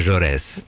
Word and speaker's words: Jaurès: Es como la calle Jean Jaurès. – Exacto. – Y esa S final se pronuncia.